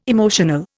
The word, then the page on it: emotional: Indian